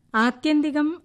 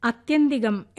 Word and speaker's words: ആത്യന്തികം: ആത്യന്തികം അർത്ഥം അവസാനത്തേത് എന്നൊക്കെയാണ്. അത് ഉച്ചരിച്ച് കേട്ടത് അത്യന്തികം എന്നാണ്.